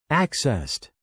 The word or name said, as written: accessed